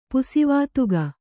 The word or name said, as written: ಪುಸಿವಾತುಗ